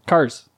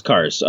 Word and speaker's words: Kars: Kars. – Kars? Kars? – Yeah, Kazar. Yeah, yeah, sure. No, it's Kars. It's a butchered ass Polish name. I guess technically it should be pronounced Karch.